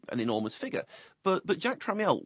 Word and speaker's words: Tramiel: Who is Jack Tramiel, is it? – I believe it's pronounced here, Tramiel.